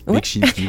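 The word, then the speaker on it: Beksiński: Principalement, alors je galère toujours à la prononcer, mais Beksinski, quelque chose comme ça. – Non, c'est bien. Beksinski, ouais.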